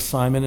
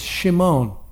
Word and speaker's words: Shimon: We pronounce it as Simon in English, but in Hebrew it's Shimon.